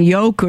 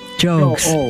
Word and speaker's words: Jogues: Easy way to remember that is think of the word jogs. – Okay. – Somebody that's jogging, but pronounce the O in jogs. – As an O. Jokes. St. Isaac Jokes.